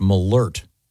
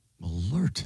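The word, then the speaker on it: Malört: And this says it's pronounced malert. – Malert. – There's a, was it an umlaut, the two dots over the O? This has a pronouncer saying malert.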